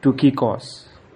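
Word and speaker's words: Tukikos (Τυχικός): Greek speaking men call him, actual name, actual pronunciation is Tukikos.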